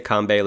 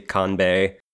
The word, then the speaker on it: Kanbei: So you could say it either way. You can say Kambe like they do in the subtitles. You say kanbei.